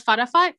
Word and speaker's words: Fatafat: If you spell it F-A-T-A-F-A-T, it's called Fudafut. And the outside is this like candy, like kind of sweet candy.